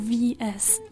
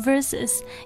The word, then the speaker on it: versus: 嗯，很多同学也许可能真的就会读 VS，其实他不是，他读 versus，因为他本来的全称是 How do you spell it? – V-E-R-S-U-S.